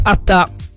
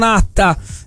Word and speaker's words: Atta: Ricordiamo che si dice Attà e non Atta. Atta era Yattaman, il personaggio dei fumetti giapponesi. Questo è Attà, con l'accento sulla, anche se non si scrive con l'accento, ma si pronuncia Attà.